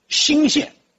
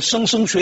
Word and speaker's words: 莘: 最早的时候，三姓主要分布在今天河南的洛阳和武陵县那一带，从宋朝开始有一部分迁到了山东的新县啊，这个字有两个读音，地名都念新，山东新县、上海新庄，但是如果念到别的成语里就念莘莘学子啊，就这个莘字，但地名念新，就迁到了新县。